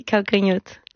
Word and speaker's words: Calcanhoto: Calcanhoto. – Oui, Calcanhoto.